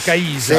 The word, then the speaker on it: Caisa: Caisa, Dovrebbe, l'accento, è sulla eh, I, quindi. Cioè, quindi. Caisa.